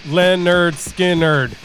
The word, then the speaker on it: Lynyrd Skynyrd: The name of the album is pronounced Lynyrd Skynyrd.